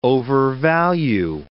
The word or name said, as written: overvalue